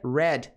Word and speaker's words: read: Então, se escreve igual, mas pronuncia red, assim como a cor, cor vermelha. Então, passado de ler é igual à pronúncia da cor vermelha.